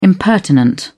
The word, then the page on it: impertinent: British English